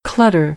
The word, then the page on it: clutter: American English; ɚ